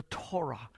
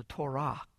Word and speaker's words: Torah: The Jews called people to accept the yoke of the Torah, or the Torah, to be correct in the pronunciation, the Hebrew pronunciation.